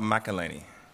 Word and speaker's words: McElhenney: Rob McElhenney.